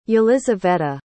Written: yelyzaveta

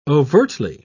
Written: overtly

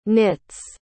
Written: knits

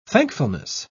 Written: thankfulness

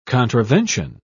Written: contravention

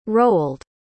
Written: roald

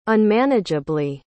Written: unmanageably

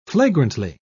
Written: flagrantly

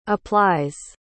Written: applies

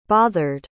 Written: bothered

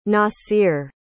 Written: nasir

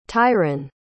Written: tyron

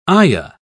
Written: ayah

